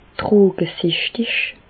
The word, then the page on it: drogesichtig: Bas-Rhin; Reichshoffen